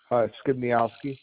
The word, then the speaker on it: Skibniowsky: Skibniowski.